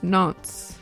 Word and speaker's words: Nantes: It's pronounced Nantes. – Is it really Mm-hmm. Nantes, France? – Nantes, France.